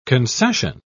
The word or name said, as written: concession